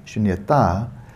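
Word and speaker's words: śūnyatā: And then we have shunyata, and that ta on the end, long A, shunyata, takes that adjective and turns it into an abstract noun.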